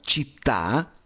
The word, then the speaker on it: città: Cuando diciamo città, te salió cita, cita o città. Cita no es muy importante porque si tú dices cita no te entendería un italiano.